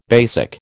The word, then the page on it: BASIC: American English